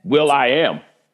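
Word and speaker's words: will.i.am: Will I Am. – Is that what it is? – Is that how you say it? – Yeah, Will I Am.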